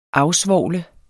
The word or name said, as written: afsvovle